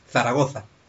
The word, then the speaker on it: Zaragoza: Bueno, no, con, el acento, perdón, Zaragoza, Entonces, este, sí, sí. Bueno, porque... – Los, de Zaragoza dicen que es la, es la, única palabra del castellano que tiene acento en todas sus, en todas sus letras vocales, ¿no? – Zaragoza.